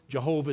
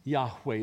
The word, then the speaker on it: Jehovah: We know Jehovah is not the correct pronunciation, but don't miss the point. Jehovah Jireh means Yahweh is my provider, one of the compound names of God of the Old Testament.